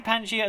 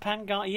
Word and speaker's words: Pangaea: So it is actually Pangaea as opposed to Pangaea.